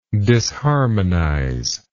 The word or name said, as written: disharmonism